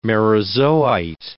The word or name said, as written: merozoite